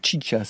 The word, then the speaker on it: Xixa: Ça se prononce Chicha, ça s'écrit X-I-X-A, mais ça prononce Chicha, comme le cinéma.